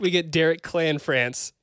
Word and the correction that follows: Cianfrance: Cianfrance. – So Derek Cianfrance. I don't know if I'm pronouncing that right either, but it sounds closer.